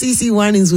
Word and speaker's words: CeCe Winans: Let's just say her name rhymes with T.T. Finans. That was CeCe Winans with Believe For It.